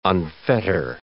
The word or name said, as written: unfetter